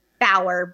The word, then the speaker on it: Bauer: It's K-A-M-I-L-L-E, Camille, and then Bauer, B as in boy, A-U-E-R, like Eddie Bauer.